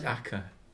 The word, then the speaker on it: Acre: Acca, yeah. It's spelled Acre, but it's Acca.